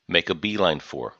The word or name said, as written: make a beeline for